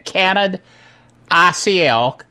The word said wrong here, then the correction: Canonical: Canonical, is changing dramatically.